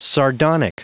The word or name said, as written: sardonic